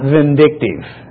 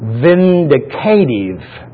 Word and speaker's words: vindicative: Justice is an expression of God's vindicative. I need to say that correctly. Not vindictive. Vindicative holiness.